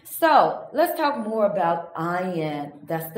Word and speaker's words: Ayin: Ayin is silent. Okay, it's pronounced it's silent when you pronounce it.